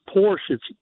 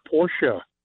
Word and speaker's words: Porsche: Everybody calls it Porsche. That's incorrect. It's Porsche. The Porsche. – Car, it's a two-syllable word.